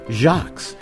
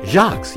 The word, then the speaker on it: Sjaaks: Your sweets for your sweetheart for Valentine's this year come with love from your local Bay Area family-owned Jacques. Take it from a chocoholic. And that's spelled S-J-A-A-K-S. What? Jacques, yes. S-J-A-A-K-S.